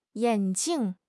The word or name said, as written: yan3 jing4